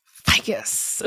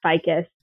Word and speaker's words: Fickas: It's nothing fun, but you're going to pronounce it as ficus, and that's okay. – Ficus.